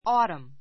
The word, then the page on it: autumn: ɔ́ːtəm; オータム